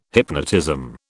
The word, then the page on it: hypnotism: British English